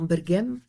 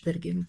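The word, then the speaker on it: Berguent: l'Algérie et le Maroc, dans la région de Bergent, où j'ai appris qu'il fallait bien prononcer en disant Bergem, comme Berguem par exemple.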